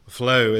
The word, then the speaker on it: Floow: Flow is spelt FLOOW, and it's in the sector of insure tech.